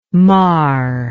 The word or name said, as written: maar